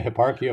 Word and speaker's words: Hipparchia: Perhaps for me the most surprising passage was the one with Hipparchia of Myronia. I think so. Is that how you pronounce it? – Yeah, Hipparchia of Myronia. Yeah.